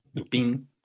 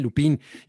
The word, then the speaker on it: Lupin: Lupin. Ellos no dicen Lupín. Y, y, y Hugo no puso que es Lupín, puso que es Lupin. Sí. Porque no lleva tilde.